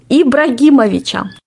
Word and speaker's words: Ибрагимовича: Сейчас меня побьют фанаты футбола, если я неправильно произношу эту фамилию. Ибрагимовича. – Правильно произнес.